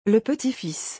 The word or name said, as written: Le petit-fils